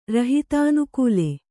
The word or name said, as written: ರಹಿತಾನುಕೂಲೆ